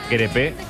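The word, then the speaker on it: Crépeau: Dice Martínez que se dice crepe, como un crepe de Nutella, de nocilla.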